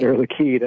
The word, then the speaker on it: Air Liquide: Air Liquide.